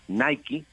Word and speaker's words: Nike: Y efectivamente, como lo mencionaba Héctor, pues es la apasionante, sí, sí, apasionante historia de cómo Nike, que así se pronuncia,